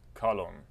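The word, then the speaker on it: Kalon (καλόν): Kalon. – But not kalon, it would be ergon. – Oh, it's kalos. Kalon. Kalon. – Kalon. Because we want the accent to persist on the second syllable, right?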